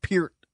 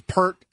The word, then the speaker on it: Peart: His last name is pronounced Peart. – Peart. – I used to think it was Pert, and then all the Rush nerds like his last name's Peart.